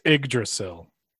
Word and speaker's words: Yggdrasil: Do you really not know how to pronounce that word? It's Yggdrasil.